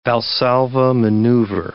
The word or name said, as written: Valsalva maneuver